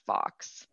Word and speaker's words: Fawkes: There was Isaac Fox, and it's spelled like Guy Fox. Well, F-A-W-K-E-S.